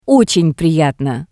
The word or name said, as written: Otsen priyatno